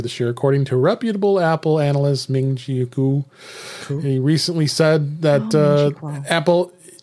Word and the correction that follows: Kuo: Ming-Chi Kuo.